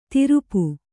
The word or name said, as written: ತಿರುಪು